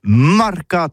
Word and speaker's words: mercat: Eh bien, dis ça à tous, mais le mercat de l'Uni ou le mercat des rebelles, mais en gascogne toulousain, c'est prononcé sur les marcats, avec un A, marcat.